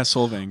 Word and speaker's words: Solvang: Yeah, Solvang.